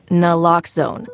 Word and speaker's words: Naloxone: For the record, it's Naloxone. Nuh, like, is Naloxone addictive? Nuh-uh. Lox, like, Naloxone keeps humans safe, like, locks keep bikes safe.